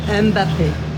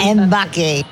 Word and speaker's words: Mbappé: Mbappé. – Mbappé.